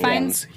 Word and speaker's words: Fiennes: Ralph Fiennes. – Ralph Fiennes.